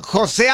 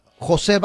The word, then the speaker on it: José: Álvaro Osorio Balvin, o sí, sea, ¿no se llama José Balvin? – Corrección, no se llama José, se llama José, sin el acento en la E.